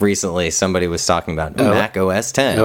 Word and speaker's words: Mac OS X: By the way, Mac OS X apparently is pronounced Mac OS 10.